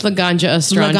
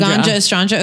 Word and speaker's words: Laganja Estranja: I think it's Laganja Estranja. – No, I think it's Laganja Estranja. – Laganja Estranja.